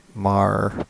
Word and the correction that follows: Mrazek: Marazek. I don't remember. I don't know how to pronounce that. Petr. – Oh, Mrazek.